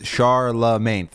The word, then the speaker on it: Charlemagne: Well, Charlemagne is Charlemagne, three syllables.